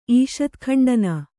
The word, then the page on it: ಈಷತ್ಖಂಡನ: kannada